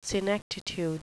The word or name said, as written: senectitude